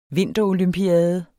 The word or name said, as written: vinterolympiade